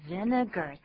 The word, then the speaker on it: vinegar: But 这个字就是念作 vinegar, 三个音节。然后 ar, 也不要念作 r, 是 er 就好了。